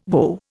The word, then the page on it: whoa: Indian